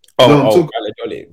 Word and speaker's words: Valladolid: Is it Charlotte, how do you pronounce it? Valladolid or whatever it's called. – Valladolid.